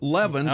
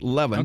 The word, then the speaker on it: Lewin: Sometimes they actually put a V in it. – Yes, right. – Sometimes he does it with a V, so I think he pronounces it Levin, okay. – but it looks like Lewin, just from the spelling, but I think he pronounced it Levin.